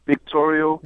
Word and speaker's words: Victorio: It's actually Victorio. – Victorio. That's a V.